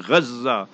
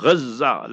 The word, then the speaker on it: Gazza: But proper pronunciation, Islamically, Arabic, Gaza. A tajdeed, a shadda on the za, Gaza, like that. So reze, you know, so like that, on that tajdeed there is G-A-Z-Z-A.